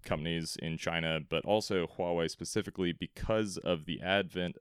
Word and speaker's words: Huawei: Hawaii. Am I pronouncing that right, Connor? – No, Huawei. – Huawei, that is right.